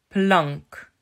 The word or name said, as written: Plank